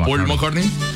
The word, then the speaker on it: McCartney: ¿McCartney? – Sí, señor. – Paul McCartney.